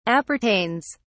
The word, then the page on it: appertains: British English